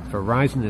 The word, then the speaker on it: Verizon: The word Verizon, pronounced the way I'm saying it, according to them, was selected from more than 8,500 names.